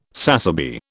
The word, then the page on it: sassaby: British English